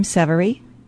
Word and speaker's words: Severy: First name, Janicki, J-A-N-A-K-I. Last name, Severy, S-E-V-E-R-Y.